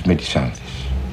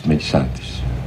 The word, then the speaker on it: Μελισάνδη: Πρέπει καταρχήν να σας εξηγήσω ότι εννοώ τη Μελισάνδη όπως εδώ προφέρεται, αλλά κανονικά είναι η Μελισάνδη, διότι αφορά τον Πελέα και Μελισάνδη του Μέτερλινγκ,